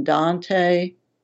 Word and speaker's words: Dante: I grew up in Richlands near Raven and Red Ash, not that far from Dante, pronounced Dant, Cedar Bluff and Jewel Ridge, among the Appalachian Mountains and along the Clinch River.